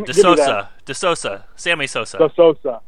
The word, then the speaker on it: De Sousa: Is it De Sousa? Because I thought I saw the other day he said it's De Sousa. – Yeah, like Sammy Sousa. De Sousa.